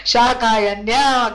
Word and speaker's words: Śākāyanya: No, wrong. Shakayanya, correct. See, this is so much fun.